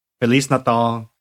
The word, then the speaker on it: Feliz Natal: Feliz Natal is how you say it in Portuguese.